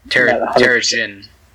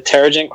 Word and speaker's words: Terrigen: How these, I think they're pronounced Terrigen clouds. I'm not... – Terrigen. – The Terrigen clouds.